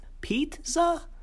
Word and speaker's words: Petza: To clarify, it's spelled P-E-T-Z-A, like the name Pete with the word za at the end. Pete-za? – Yeah, and Pez happens to work there.